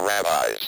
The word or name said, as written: rabbis